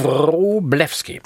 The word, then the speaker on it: Wroblewski: Habe ich es richtig ausgesprochen? – Ja. – Wroblewski.